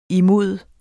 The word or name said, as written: imod